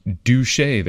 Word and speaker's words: Duché: And we know how that's how you pronounce it. It's spelled D-U-C-H-E with an accent over the E. But we know how to pronounce it because Duché, they pronounce it, Adams wrote in a letter.